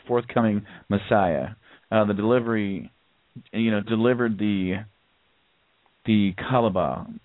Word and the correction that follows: Cabala: or it's spelled C-A-B-A-L-A. – Kabbalah to humankind.